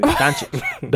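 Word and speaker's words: Dončić: Did I say it right? Luka Doncic? Luka Doncic? – Doncic. – Doncic. – Yeah.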